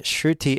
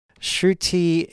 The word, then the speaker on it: Shruti: And the transliteration of Sanskrit, Shruti is spelled S-R-U-T-I, and Smriti is spelled S-M-R-I-T-I. And both have diacritical marks to further demonstrate the correct pronunciation. I don't claim to have perfect pronunciation of Sanskrit by any means. I think I'm fairly close on those two words, though.